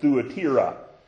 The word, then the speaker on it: Thyatira: Or I've heard some pronounce it Thuatira. And they say it's how you're supposed to pronounce it. It don't look like it to me. But that's what I've heard.